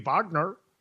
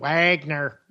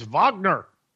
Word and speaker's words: Wagner: Russian President Vladimir Putin in world news pledged in a televised address that the leaders of the Wagner Rebellion, or could be Wagner, you know, Richard Wagner, the German composer. Yeah, everybody call it Wagner. No, in German it's Wagner.